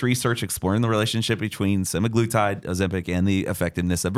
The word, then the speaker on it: semaglutide: Is there a connection between, is it semaglutide? Is that how you say it? – Yeah.